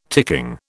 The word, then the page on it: ticking: British English